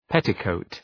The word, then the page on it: petticoat: ‘petı,kəʋt